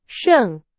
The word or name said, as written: 胜